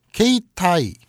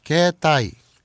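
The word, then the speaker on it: 게타이 (携帯): 일본어 중에 A가 E로 길게 발음되는 경우가 많아요. 너무 성실하게 A 안 하셔도 될 듯. 일본 애들 A를 A라고 쓰고 에라고 읽잖아요. 그렇죠? – 예. – A, B, C, D 이렇게 읽지 않죠. 음. 일본 애들은 에, B, C, D라고 음. 하죠. 게이타이, 게타이. 그러니까 게이타이 그 휴대폰을 네. 게타이라고 하는데 게이타이라고 쓰지만 음.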